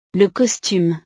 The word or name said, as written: le costume